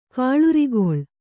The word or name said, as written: ಕುಳಿರ್ಕೊಳ್